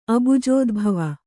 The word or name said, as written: ಅಬುಜೋದ್ಭವ